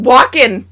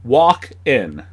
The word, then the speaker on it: Walken: Stop over-enunciating the L. It's just Walken. – Walken. – Walken. – Walk-in.